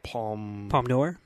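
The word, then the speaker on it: Palme d'Or: I always forget how to pronounce the name of the Palm d'Or? – Yeah.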